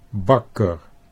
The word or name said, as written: Bakker